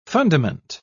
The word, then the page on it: fundament: British English